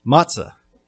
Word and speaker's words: matzah: And that Hebrew word, best I can tell from the transliteration, is pronounced matzah.